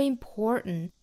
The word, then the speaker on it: important: important more 和 important 因为单词内部含有字母 r 都需要卷舌成 more important 同样是鼻爆音那鼻爆呢在英音和美音里面都是有的英音这个词我们是读成 important 但是美音是要读成 important 就好像是一个 n 的发音但其实是由鼻腔爆破出来发音的 important 大家去找一